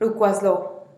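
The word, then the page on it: rukwazlo: Teotitlán del Valle Zapotec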